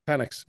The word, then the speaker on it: Penix: Michael Penix Jr. Penix, yeah.